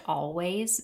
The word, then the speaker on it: Alvvays: I think it's pronounced Always, but it's spelled Alvvays.